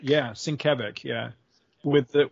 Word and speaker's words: Sienkiewicz: Because I mispronounced Sienkiewicz for the longest time.